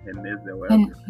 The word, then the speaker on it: Renée Zellweger: Renée Zellweger.